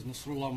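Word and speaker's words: Nasrulah: My name is Nasrullah Mohammed. That is spelled N-A-S-R-U-L-A-H-M-O-H-A-M-E-D.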